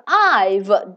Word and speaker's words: dive: OK，the dive dive，它的这个拼写就是什么呀？D I V E，对不对？非常简单，怎么拼的怎么写？the dive dive，最后有一个不发音的 e，对不对？